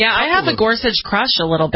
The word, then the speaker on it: Gorsuch: It's Gorsuch, right? – Gorsuch, yeah.